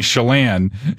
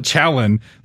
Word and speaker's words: Chelan: Like, there's an objective way of saying Chelan, and you can't call it Chalan.